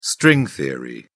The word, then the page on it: string theory: British English